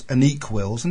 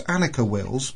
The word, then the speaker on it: Annika Wills: It's like, I'm going to change the subject because Siobhan Galichon and David Keep were insisted on calling Annika Wills Anique Wills until they happened to meet her. And suddenly now they're calling her Annika Wills.